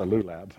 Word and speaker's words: lulab: The connection was so strong that many Jews referred to their lulabs, that's this, sounds like a kind of a dog, right? This is a lab and that's a lulab, but lulab, they referred to them as Hosannas.